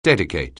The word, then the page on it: dedicate: anglais